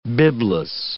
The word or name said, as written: bibless